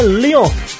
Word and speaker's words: Lyon: É Lyon. Nos pênaltis. É, fatura a Copa da Liga e completa a tríplice coroa nacional da temporada. É assim mesmo a, a, a pronúncia do time é Lyon mesmo, né?